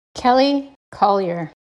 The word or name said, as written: Kelly Collier